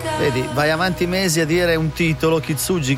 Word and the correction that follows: Kintsugi: Kitsugi, Kitsugi, poi scopri dal testo che è Kitsugi. Kitsugi la pronuncia esatta. – Senza la N? – Kintsugi. – Kintsugi. Ah, ok, Kintsugi.